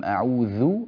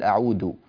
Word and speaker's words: أعوذ: قوله تعالى يعني قبل أن ندخل في التلاوة أصلا في الاستعاذة، قل أعوذ بالله من الشيطان الرجيم، أعوذ بالذال لا تقل أعود، كذلك الرجيم لا تقل جيم، الرجيم هكذا تنطق الجيم.